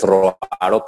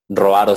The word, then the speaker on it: Roaro: Eh, porque me encuentras fácil, me llamo Andrés Roaro, pero mi apellido no es muy, muy común, este, pero bueno, entonces por eso, por eso decía lo de las notas, ¿no? Este, eh, Roaro se escribe R-O-A-R-O y pues este,